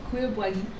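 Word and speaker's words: cuir bouilli: How do you pronounce that? – Oh, Cure Bully. – Okay, much better.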